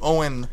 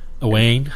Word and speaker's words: Owain: When Brigadier Lethbridge Stewart and his fiancée Sally Wright and nephew Owen... Is it Owen or Owain? Owain? – Owain. – Owain. – In my head, I pronounce it Owain. – Yeah, it's spelled Owain, so...